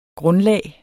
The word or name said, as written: grundlag